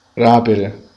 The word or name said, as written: aprire